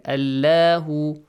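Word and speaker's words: Allahu (الله): The word Allah is pronounced heavily here, yes? You don't have to read it. Allahu, Allahu.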